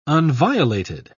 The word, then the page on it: unviolated: British English